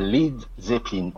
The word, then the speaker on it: Led Zeppelin: Un Led Zeppelin en VO. Page proposa d'enlever le A de Led pour une prononciation plus correcte et plus fluide aux États-Unis, et le tour était joué.